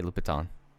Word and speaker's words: Louboutin: Well, the other one that I was disputing was it's pronounced Lubatins. – Pretty sure that's not true. – Yeah, that's not. – That was my point. The French pronunciation is Lubatin.